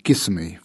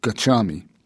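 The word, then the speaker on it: gacchāmi: Since much of the ordination was conducted in Burmese Pali, that is, in Pali chanted in the guttural Burmese fashion with many mispronunciations, for example, Tissa for Tassa and Gissami for Gachami, I was unable to follow it very well.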